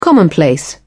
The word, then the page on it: commonplace: British English